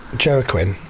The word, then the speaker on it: Joaquin: Joaquin? – Yeah, Joaquin.